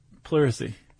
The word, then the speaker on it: Pleurisy: How do you say that? Pleurisy? – Pleurisy.